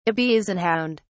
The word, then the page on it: Ibizan hound: British English